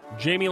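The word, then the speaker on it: Jaime: Did Jamie, is it pronounced Jamie? – Jamie Lannister? – Yeah, Jamie Lannister.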